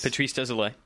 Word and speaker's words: Patrice Désilets: Patrice Désilets.